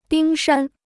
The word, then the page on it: 冰山: bīng shān